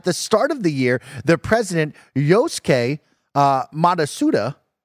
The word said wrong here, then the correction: Matsuda: Uh, said Matsuda. Matsuda, thank you.